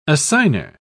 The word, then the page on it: assigner: British English